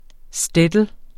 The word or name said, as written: shtetl